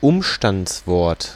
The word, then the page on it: adverbe: /ad.vɛʁb/